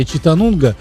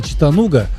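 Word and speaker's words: Chattanooga: Да, а все остальные, как бы, дети пионерских лагерях всегда называли «Читанунга» почему-то, потому что фильм не видели и не видели, как пишется это название. И только потом, спустя многие годы, обнаруживали, что город называется «Читануга», поэтому никакая она не «Нунга», а «Нуга».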